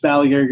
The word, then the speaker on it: Balaguer: Just Balaguer Guitars on Instagram and Facebook and Twitter and all that stuff.